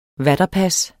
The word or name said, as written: VATERPASSENES